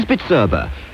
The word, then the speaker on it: Bizerba: The manufacturer of the finest high-tech weighing, slicing and price labeling machinery is Bitserba. B-I-Z-E-R-B-A. Pronounced Bitserba. So now you can pronounce it.